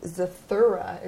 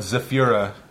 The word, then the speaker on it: Zathura: On the lighter side, I was very impressed with Zathura. Is that how you say it? – Yeah. Well, see, that's pretty good. That was a movie Zathura! That people couldn't pronounce very well. Sometimes we called it Zafira in the editing room.